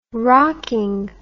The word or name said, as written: rocking